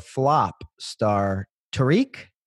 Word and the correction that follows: Tarek: Tarek. – Tarek. Say it's spelled like Tariq.